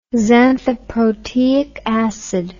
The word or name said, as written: xanthoproteic acid